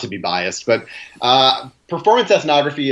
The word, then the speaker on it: ethnography: How about helping us understand, me too, for sure, what is performance ethnography? I'm probably even pronouncing it wrong. – Yeah, ethnography, sure. – Ethnography, there you go.